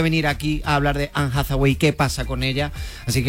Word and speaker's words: Anne Hathaway: Anne Hathaway. – Eva, ¿está bien dicho? Está perfectamente dicho.